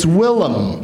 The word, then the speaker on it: Willem: I really think it should be a rule that you have to pronounce the name correctly. It's not William. It's Willem.